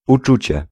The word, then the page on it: uczucie: /uˈt͡ʂut͡ɕɛ/